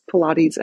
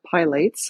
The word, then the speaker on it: Pilates: So first of all, it's pronounced Pilates and not Pilates, which I get a lot.